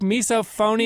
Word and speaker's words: misophonia: I'm going to screw it up, the pronunciation, but it's a condition that Elizabeth is writing about today called misophonia. Elizabeth? – Hey, how are you? – Do I have that correct? Misophonia? – You have it right. Miso or misophonia.